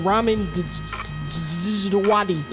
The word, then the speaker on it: Djawadi: Ramin Djawadi.